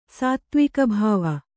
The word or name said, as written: ಸಾತ್ತ್ವಿಕಭಾವ